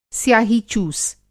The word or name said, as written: स्याही-चूस